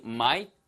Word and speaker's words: might: M-I-G-H-T의 발음을 might 하고 을을 붙이시면 안 됩니다. 영어에는 을라는 소리가 없어요. 한국어의 을 소리는 되게 음가가 강하거든요. 영어에서 이렇게 슬쩍 지나가는 듯한 을하고는 음가가 달라요. 사운드 밸류가 다릅니다. 그래서 might, sleep in 이렇게 하시면 안 돼요.